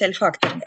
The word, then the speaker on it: ольфакторный: То есть, вот, в принципе, правильно говорить альфакторный. Альфакторный — это обонятельный, по сути дела.